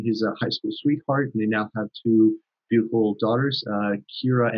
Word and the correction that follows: Kiara: and Gina, if I pronounce their names right. – Yeah, Kiara and Gianna.